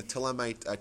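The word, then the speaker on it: Ptolemaic: And the Ptolemite, Ptolemite is spelled with a P-T, like you don't pronounce the P. The Ptolemite kingdom, which is Egypt.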